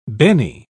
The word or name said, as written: Benny